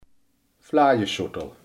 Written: vlaaischotel

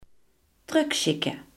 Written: terugsturen

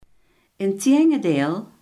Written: integendeel

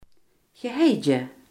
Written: gehaaide

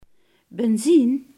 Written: benzine

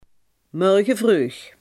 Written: morgenvroeg

